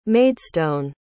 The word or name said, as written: maidstone